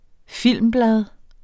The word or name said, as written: filmblad